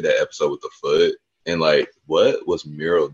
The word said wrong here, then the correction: Muriel: doing? She wanted, I'm saying that so wrong. I can't form her name with my mouth. – Muriel? – I feel like I'm saying that wrong. – Muriel? – Muriel. Okay. Anyway, the one with the foot fun guy, like, she made him put his foot in, like, a bucket of crabs.